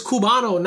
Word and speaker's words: Cubano: Somebody from Cuba is Cubano, not Cubano.